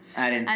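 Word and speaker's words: Ahrendts: Arends.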